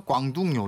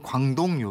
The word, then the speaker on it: 광둥: 광둥 요리. – 예. 예, 예. 우리 발음으로 광동 요리.